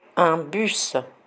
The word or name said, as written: un bus